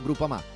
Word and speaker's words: Groupama: DJ Biancorosso, Groupama, si pronuncia Groupama, di Paolo Felicetti.